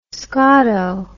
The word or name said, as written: scotto